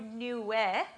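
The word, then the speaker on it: Nuweh (נועה): It's actually pronounced in Hebrew, Nuweh, okay?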